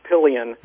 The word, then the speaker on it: Papillion: Yeah, that's the French version. We've actually Englishized it, and it's pronounced Papillion.